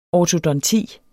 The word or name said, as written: ortodonti